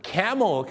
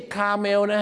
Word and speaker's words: camel: camel ครับผม camel ไม่ใช่คาเมลนะ